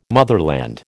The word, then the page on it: motherland: American English